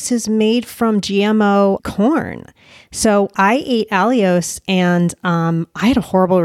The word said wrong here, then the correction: allulose: So I'm surprised you said Alios. – Allulose. – Allulose. Sorry, I always pronounce it wrong.